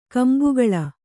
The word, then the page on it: ಕೊಂಬುಗೊಳ್: kombugoḷ